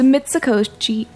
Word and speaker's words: Mitsukoshi: Did I say it weird? – You just said Mitsukoshi. – Yeah, it's Mitsukoshi.